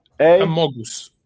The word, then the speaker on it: Amogus: M-O-N? – M-O-G-U-S. – M-O-G-U-S. Mogus. – No. Let me send you the Among Us with an A. Amogus. The ticker symbol is A-M-O-G-U-S. Amogus. – G-U-S. Amogus. Amogus on Solana.